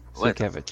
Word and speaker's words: Sienkiewicz: Bill Sienkiewicz. – Sienkiewicz. – To say his name correctly. – Sienkiewicz.